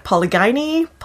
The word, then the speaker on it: polygyny: I found it really fascinating, some of the stuff that you talked about with, I'm not even sure how to say it, polygyny? – Mm-hmm.